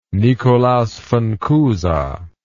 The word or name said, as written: Nikolaus von Cusa